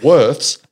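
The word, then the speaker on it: Würth: Worth's make the best hand soap. It's Worth. – Worth. Yeah. So I think the Germans pronounce it Worth's, but, yeah, it's the automotive brand.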